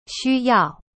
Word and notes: 需要: xu yao

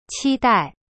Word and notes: 期待: qi-dai

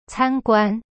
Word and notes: 参观: can guan

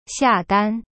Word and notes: 下单: xia dan

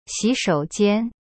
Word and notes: 洗手间: xi-shou-jian

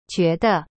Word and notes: 觉得: jue de

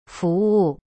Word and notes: 服务: fu wu

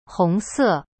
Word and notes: 红色: hong se

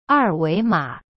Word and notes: 二维码: er wei ma

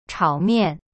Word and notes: 炒面: chao mian

